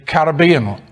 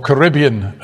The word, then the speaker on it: Caribbean: Ashiel Blaise was born in Dominica, in the Caribbean, or Caribbean, as you insist on pronouncing it, in 1941.